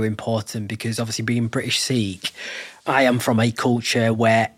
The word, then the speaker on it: Sikhi: It's Sikh, Sikhi is how we pronounce it in Punjabi, the word means learning.